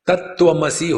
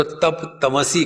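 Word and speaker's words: तत्त्वमसि: बंगालियों में आ, जो कंजंग कॉन्सेंट होता है जोड़ाक्षर वो ठीक से उच्चारण नहीं कर सकते जैसे आ, आत्मा होगा तो आत्मा को अत्ता कहेंगे तत्वमसी होगा तो तप तमसी कहेंगे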